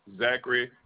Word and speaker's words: Zachary: It's Zachary Carter. – Is it Z-A-C-A-A-R-Y? – Z-A-C-H-A-R-Y.